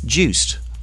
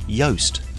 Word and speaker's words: Joost: Next, Juiced, or as it's meant to be pronounced, Yoast. This is a new IPTV service from the people that brought you Kazaa file sharing and the hugely popular voice over IP service Skype. Yoast, spelt J-O-O-S-T, will be offering a full-screen TV service over broadband with a mix of TV programs and internet video.